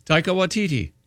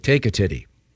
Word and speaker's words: Taika Waititi: Taika Waititi. – Okay, I thought it was like take a titty.